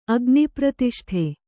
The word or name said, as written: ಅಗ್ನಿಪ್ರತಿಷ್ಠೆ